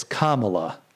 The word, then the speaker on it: Kamala: I think that's how you say her name. It's not Kamala, and it's not Kamala. It's Kamala. Kamala.